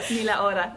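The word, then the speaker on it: Ni la hora: All right, so you were just listening to, I'm going to try it, Nila, oh, I can't do that. Nila Hora? I'm trying. – Nila Hora.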